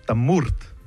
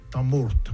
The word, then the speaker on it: Tamourte: Et ça se prononce Tamourte. – Tamourte, ouais.